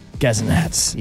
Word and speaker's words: Gazanats: Say it like how I did. – Gazanats. – Yes.